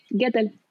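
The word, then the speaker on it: Guettel: ¿Estoy pronunciándolo bien? – Sí, Gettle. Adam y está basado en la novela de Elizabeth Spencer.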